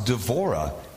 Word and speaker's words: Deborah: Today's character, Deborah, is actually pronounced Devorah in Hebrew.